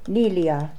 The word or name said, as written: Nilia